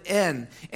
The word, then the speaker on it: en: The Greek word for "with," in case you want to know, is the word, we transliterate it into the word en. We pronounce it en, and it means in, with, or by.